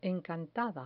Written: encantada

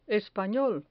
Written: espanol

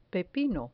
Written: pepino

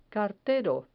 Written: cartero

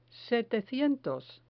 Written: setecientos

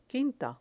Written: quinta

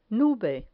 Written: nube

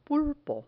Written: pulpo

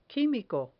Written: quimico

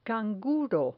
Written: canguro

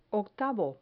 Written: octavo

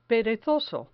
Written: perezoso

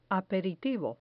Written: aperitivo

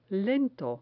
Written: lento

